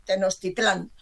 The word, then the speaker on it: Tenochtitlán: En 1521, Hernán Cortés conquistó Tenochtitlán.